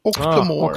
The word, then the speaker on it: Octomore: jag är ganska säker på att det uttalas Ochtamor. – Octomore. Ah, oct ja, just det. Octomore. – Octomore brukar folk säga. Själv. Jag tror att det heter Octamore.